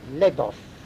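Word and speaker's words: Labov: qui s'appelle Lebov. Je crois que ça se prononce comme ça, je ne suis pas sûr. L-A-B-O-V. L-A-B-O-V. L-A-B-O-V. Lebov. Ça va, Lebov? Oui.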